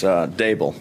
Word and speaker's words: Daboll: Yeah, so it's Daboll. D, like table. – I played that because Hershey throws Daboll in the chat. It's Daboll, like table. Obviously.